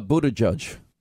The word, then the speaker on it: Buttigieg: Buttigieg. No, no, no. Uh, Buttigieg.